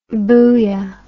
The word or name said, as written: booyah